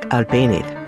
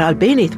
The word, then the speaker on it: Albéniz: The name Albéniz is spelled with a Z at the end. In Latin America, that Z is pronounced S, but in Spain, they say it with a TH sound.